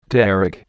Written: derrick

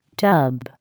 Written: tub

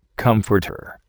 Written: comforter

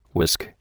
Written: whisk

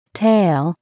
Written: tail

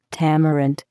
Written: tamarind

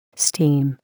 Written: steam

